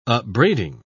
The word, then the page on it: upbraiding: British English